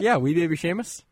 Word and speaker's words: Seamus: Is it Seamus? – Yeah. – There's an implied H? – Yeah.